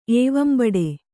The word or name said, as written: ಏವಂಬಡೆ